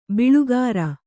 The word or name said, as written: ಬೊಳ್ಳುಗರೆ